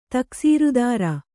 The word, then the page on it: ತಕ್ಸೀರುದಾರ: Kannada